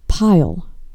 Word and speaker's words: Payal: I said, the way you spell it is not how you, the way you say it. Pile, like P-I-L-E, like a pile of books.